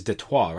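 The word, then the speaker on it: Détroit: I guess it's Detroit, right? As the French would pronounce it. – That is perfect.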